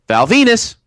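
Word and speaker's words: Val Venis: Val Venus. – Rhymes with the penis.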